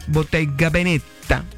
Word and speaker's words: Bottega Veneta: O el más económico dentro de los bolsos de Yera Ponce es el Bottega Veneta, porque así se pronuncia, 15 millones.